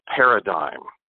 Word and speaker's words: Pyradym: August Worley invented what he calls paradigm, and that's spelled P-Y-R-A-D-Y-M.